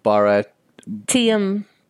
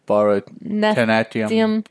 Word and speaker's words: Bharatanatyam: Bharatanatyam. Natyam. Bharatanatyam.